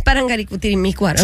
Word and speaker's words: Parangaricutirimícuaro: Pero el mío es Parangaricutirimícuaro. – Sí que... – Por eso lo dije, el primero lo dije Parangutirimícuaro. – No. Te falta una sílaba, pero dale.